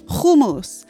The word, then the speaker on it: hummus: or as she correctly pronounces it, hummus, as well as the delicious eggplant dish known as sabich, to a hungry American audience.